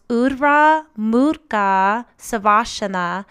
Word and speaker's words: Urdhva Mukha Svanasana: Now, in Sanskrit, this is pronounced Urdhva Murkha Savasana, and it is a back-bending yoga posture that lengthens and strengthens the spine, torso, and arms.